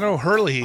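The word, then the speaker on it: O'Herlihy: I always say this wrong. Dan O'Hurley.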